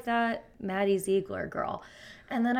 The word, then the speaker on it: Zegler: I thought that him and Rachel Ziegler, is that how you say her name? – I say Zegler, but only because there's no I.